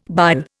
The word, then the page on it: bile: Indian